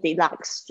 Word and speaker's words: deluxe: 那这个 deluxe 就是有包含 suite，但是又是升级的。 OK，deluxe，注意一下它的发音，它的 e 不发音。